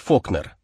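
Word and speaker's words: Falkner: Тогда его фамилия еще писалась без буквы «Ю». Фолкнер и произносилась как Фокнер, а вырос в расположенном неподалеку городке Оксфорд на севере штата, куда он перебрался вместе с родителями.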